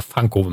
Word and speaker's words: Funko: Funko Pops oder Funko, wenn man es richtig aussprechen möchte.